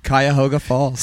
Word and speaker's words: Cuyahoga Falls: Cuyahoga Falls.